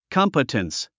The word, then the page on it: competence: kάmpətəns; カンパテンス